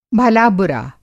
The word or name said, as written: भला-बुरा